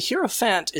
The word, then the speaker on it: Hierophant: Now, for our second to last category, we are going to bring in the totally killer awesome card, the Hierophant. Did I pronounce that correctly? – Yes, the Hierophant is kind of an interesting card.